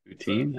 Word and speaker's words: Poutine: But okay, am I saying it right again? Poutine? – Poutine, that's okay.